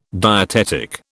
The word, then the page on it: dietetic: British English